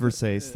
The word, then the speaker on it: Versace: It's pronounced Versace, thank you.